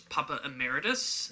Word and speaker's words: Papa Emeritus: character's name, and I'm going to say this wrong, I'm sure it was Papa Emeritus.